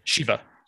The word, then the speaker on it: Shiva: Shiva. Shiva's right. I'm sorry. – Oh, is it Shiva? – I am Jewish. I should know. It's Shiva. Yeah, sitting Shiva, seven.